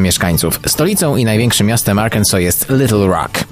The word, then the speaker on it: Arkansas: Choć w naszym kraju najczęściej mówi się Arkansas, poprawna wymowa to Arkansas.